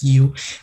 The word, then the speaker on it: Yow: And it's pronounced as Yu.